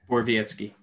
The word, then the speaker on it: Borowiecki: We also have in here Mark, I can't say his name, Borowiecki? – Borowiecki.